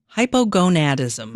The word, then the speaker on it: hypogonadism: So late onset hypogonadism.